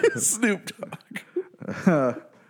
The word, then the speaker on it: Snoop Dogg: I might butcher this next name as Snopdog. – Snopdog. Yeah. – Snoop Dogg.